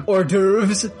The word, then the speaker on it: hors d'oeuvres: There's waiters and waitresses serving hors d'oeuvres. That's how you say it, right? – That's exactly how you say it.